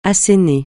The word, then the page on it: assener: francais